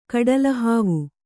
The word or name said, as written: ಕಡಲಹಾವು